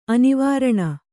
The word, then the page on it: ಅನುವರ್ಣ: anuvarṇa